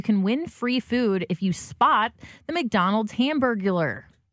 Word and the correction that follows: Hamburglar: I feel like I'm saying that wrong. – You are. There's something extra in there. Yeah. Let's try that again. – No, usually there's an extra syllable. That had an extra sentence. – Yeah, it did.